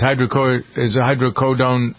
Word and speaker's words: hydrocodone: Agency says hydrocodone.